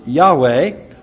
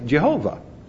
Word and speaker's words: Yahweh: In order to say that name, you have to put an A in the first syllable and an E in the other one, and the name becomes Yahweh, or, as we very often say, Jehovah.